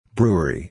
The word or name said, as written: brewery